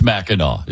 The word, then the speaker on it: Mackinac: First of all, It's spelled, why do they spell it Mackinac when it's Mackinac? It doesn't make any sense.